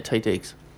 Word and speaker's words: Taye Diggs: That is how you say it, Taye Diggs. – Mm. Well, I just did. – T-A-Y-E.